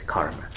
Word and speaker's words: karma: Anyway, so in the Pali pronunciation is kamma, and then in Sanskrit is karma. So sometimes in these quotes, you're going to find that it's spelled as K-A-M-M-A, just so you know.